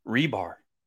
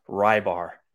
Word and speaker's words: Reebs: His pronunciation of his name is Lord Rebar, not Lord Rybar.